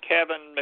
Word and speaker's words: Keven: One is Kevin McQueen. Remember, my name is spelled K-E-V-E-N.